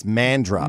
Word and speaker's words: Mandurah: Well, it's confusing too because there's a Mandurah. – Yes. – So there's Mandurah in New South Wales, but if you're over in WA, it's Mandra. – Mandra. – But it's spelled exactly the same.